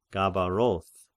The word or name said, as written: Gabaroth